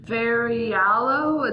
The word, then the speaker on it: Ferraiolo: It's Jack D. Ferraiolo. It's F-E-R-R-A-I-O-L-O. Ferraiolo, I guess, works.